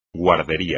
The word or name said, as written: guardería